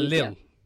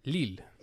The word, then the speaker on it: Lille: Lille. – That one. – Lille.